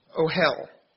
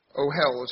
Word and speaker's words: ohel: And the Hebrew word for tabernacle here is pronounced ohel. Ohel is spelled O-H-E-L.